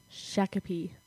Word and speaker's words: Shakopee: Shakopee, and that's spelled Shakopee, Minnesota, well, MN, 55379. – Interesting. Shakopee. Yeah. – I know. It sounds like Chicopee up in Massachusetts, but it's Shakopee. – Shakopee.